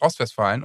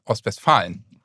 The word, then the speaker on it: Ostwestfalen: ich Revolver hab, wir haben letztens gesprochen, dann habe ich gesagt Ostwestfalen und das ist natürlich ein totaler Fehler. Ostwestfalen.